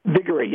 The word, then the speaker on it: Viguerie: Am I pronouncing his name right? – Viguerie, yes. – Viguerie, yeah.